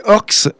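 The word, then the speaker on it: Horskh: C'est Orks. Je crois que c'est comme ça que ça se dit. Ça s'écrit H-O-R-S-K-H. C'est un trio euh, franc-comtois originaire de Besançon, fondé en 2014 par euh, Bastien Hénaud.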